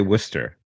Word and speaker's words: Worcester: I never know how to pronounce that. They all say Worcester.